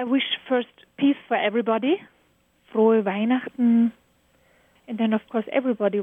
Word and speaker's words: Frohe Weihnachten: Frohe Weihnachten.